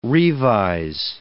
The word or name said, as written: revise